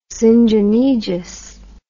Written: syngenesious

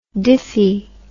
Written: diffy

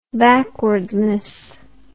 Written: backwardness

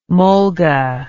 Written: mulga

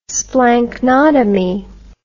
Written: splanchnotomy

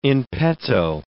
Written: in petto

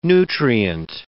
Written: nutrient

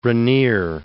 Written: Rainier III

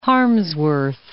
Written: Harmsworth

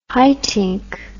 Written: Haitink

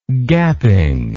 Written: gapping

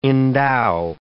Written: endow

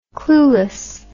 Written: cluelessly

